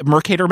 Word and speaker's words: Mercator: Yeah, and the Mercator map, is that the? Am I saying that correctly? – Yes.